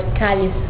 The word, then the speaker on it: Cálice: And there's one, Cálice. Cálice is like chalice, C-A-L-I-C-E. Well, it sounds like the word Cálice. Cálice means chalice in Portuguese.